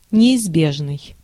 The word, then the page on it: неизбежный: /nʲɪːzˈbʲeʐnɨj/